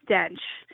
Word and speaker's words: D'Oench: Um, so my Twitter handle is at me m e underscore yay y a y underscore dench. Um, so it's the it's the um, phonetic pronunciation okay. of my of my name. Got it.